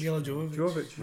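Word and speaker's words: Milla Jovovich: Mila Jovovich.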